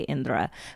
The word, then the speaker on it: Indra: It's Indra. You're pronouncing it, if you're like a native Hindi speaker or you've been around like Indian accents your whole life, you should be able to say Indra.